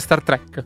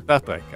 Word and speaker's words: Star Trek: No, non so, ho sempre sentito dire Star Trek. – Star Trek.